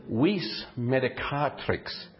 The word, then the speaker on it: vis medicatrix: You can get it on the computer now, a little thing that pronounces it for you. And I played it and that said vis medicatrix.